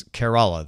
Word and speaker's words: Kerala: He says it's Kerala, the same as care, as in caring for someone.